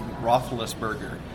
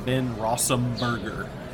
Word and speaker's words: Roethlisberger: He claimed to not be able to say Roethlisberger. He called him Ben Rossumberger. And then he'd giggle and say, oh, I can't say his name. That's the closest I came is Rossumberger.